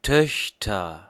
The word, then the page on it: Töchter: /ˈtœç.tɐ/